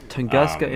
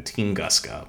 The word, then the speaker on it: Tunguska: Tunguska impact. How do you spell that? Tunguska? – So I heard it pronounced two ways. There's Tunguska and then Tinguska, but it's spelled Tunguska.